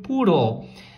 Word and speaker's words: poodle: I have a little, um pequeno poodle. Um poodle pequeno, né? Não confunda a pronúncia de poodle com puddle, que eu já ensinei também.